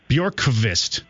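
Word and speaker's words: Björkqvist: Bjorkvist. Bjorkvist? – Yep, the V is pronounced like a U. So even though it's Bjorkvist, you treat that V like a U.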